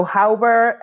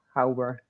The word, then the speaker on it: Hauber: Yeah, so Hauber is spelled, pronounced H-O-W. How are you doing? And burr, like sand burr, burr is cold. Yeah, Hauber.